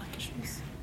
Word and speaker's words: Akershus: Ja, Akershus.